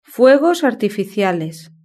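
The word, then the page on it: fuegos artificiales: Spain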